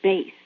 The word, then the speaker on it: Bass: It is often mispronounced as bass, as in bass guitar. So I usually say, it's Bass, like the fish.